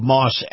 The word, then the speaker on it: Maus: you'll love Moss Acura. Now, Moss is spelled M-A-U-S.